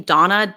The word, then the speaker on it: Donna: my Twitter is my first name, Donna, D-O-N-N-A. My last name, Imam. It's spelled I-M-A-M.